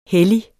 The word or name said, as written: hellige